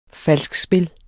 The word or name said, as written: falskspil